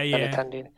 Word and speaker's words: Benintendi: Benintendi. – Yeah. – Benintendi.